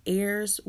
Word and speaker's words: heirs: It says, heirs with Christ. And that word is spelled H-E-I-R-S, which means inherit, but it's pronounced heir.